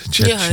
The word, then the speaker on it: ChatGPT: ChatGPT.